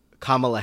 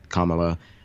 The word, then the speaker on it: Kamala: Yeah, it's that one's kind of strange because it can be pronounced both ways. I think I know another Indian woman whose name is Kamala, and she pronounces it Kamala, Kamala. And there's a superhero, a Marvel superhero, Ms. Marvel, and her name is Kamala Khan. So like, I'm always like going back and forth between Kamala Harris, Kamala.